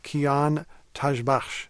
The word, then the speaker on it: Kian Tajbakhsh: I want to bring up another name here, Kian Tajbakhsh. Am I saying that correctly? – Perfect.